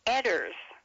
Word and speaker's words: Edwards: His name was Edwards, E-W-E-D-W-A-R-D-S, but he pronounced it Edders.